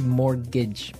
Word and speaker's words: mortgage: Yeah, it has a T in it, but you don't pronounce it. You say mortgage.